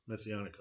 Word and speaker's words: Messianic: which do refer to a Masonic hope. – Right. Right. – Messianic hope. – That's right. It's the morning for mispronunciations.